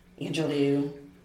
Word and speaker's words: Angelou: I'm a huge fan of Dr. Maya Angelou. Angelou, right? We just heard the pronunciation.